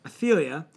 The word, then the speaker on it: Athaliah: Athaliah.